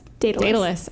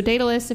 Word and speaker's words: Daedalus: Daedalus. – Okay. So Daedalus, if you're not familiar, he's famous for the story of Daedalus and Icarus.